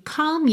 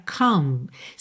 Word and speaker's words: comb: Começando então, I see you comb your hair. Olha só que interessante essa palavra comb. Se escreve C-O-M-B, mas você não pronuncia o B, você não diz comb.